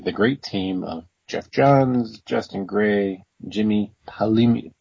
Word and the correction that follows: Palmiotti: I said it right before we recorded. – Yeah, you did. You did it. – Him. – Yeah, Jimmy Palmiotti.